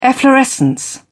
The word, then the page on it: efflorescence: British English